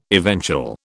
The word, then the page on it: eventual: British English